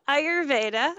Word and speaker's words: Ayurveda: And that's, so is Vedic like a derivative of oh my God, I'm going to pronounce this wrong. Ayurvedic, whatever it is. You know, my wife said, wow, I butchered that, but you know what I'm saying, right? – Yeah. Ayurveda.